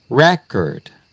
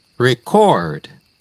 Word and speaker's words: record: Si es un sustantivo, tiene énfasis en la primera sílaba. Record. Un record o registro. Ejemplo, rompía récord de la escuela en los 100 metros. I broke the school record in the 100 meters. En cambio, si es verbo, el stress va en la segunda sílaba.